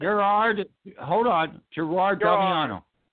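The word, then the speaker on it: Gerard Damiano: And that's Gerald Domino's The Devil and Miss Jones. – Let's say his name right. Gerard Domino.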